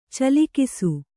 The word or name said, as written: ಚಿಲುಕಿಸು